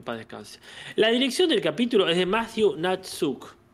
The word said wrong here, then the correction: Nastuk: con Nastuk, en realidad